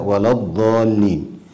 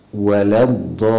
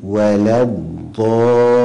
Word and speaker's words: ولا الضالين: ومع المقطع الأخير في الآية الأخيرة غير المغضوب عليهم ولا الضالين. أولا، من الأخطاء تفخيم الواو في كلمة ولا الضالين وكذا اللام، فتجد القارئ يقرأ هكذا ولا الضالين، وهذا خطأ. والصواب ولا الضالين، ولا الضالين.